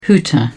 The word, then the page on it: hooter: British English